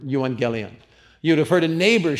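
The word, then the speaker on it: Euangelion (εὐαγγέλιον): But the word in the Greek there, and I want you to pronounce it with me. Here's how it goes. Euangelion. Try that. Euangelion.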